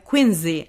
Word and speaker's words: Quincy: Quincy, actually. That's the right pronunciation.